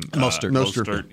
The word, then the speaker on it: Mostert: Mostert. – Yeah. Mostert.